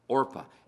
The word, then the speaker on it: Orpah: I got to say that right because I'm used to pronouncing it the wrong way. Orpah.